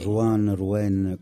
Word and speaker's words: Rouen: Senti, noi ci ascoltiamo Rouen, si dice, è corretto? Corretta la pronuncia? – Sì, i francesi sono un po' difficili. Sì. Rouen.